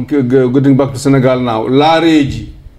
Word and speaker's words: Laareejy: In Fulani, we call those spirits Lareji. – Say it again. – Lareji. – How you spell it? – L A A R E E J Y. Lareji.